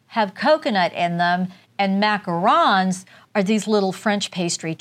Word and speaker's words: macarons: So these little cookies here, I thought these were called, the little cookies with the chicks on, I thought they were called macaroons. Oh, no, no, no. They're called macarons.